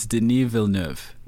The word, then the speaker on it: Denis Villeneuve: Can you pronounce his last name? – It's Denis Villeneuve. – Yeah, which is not even close to how it's spelled.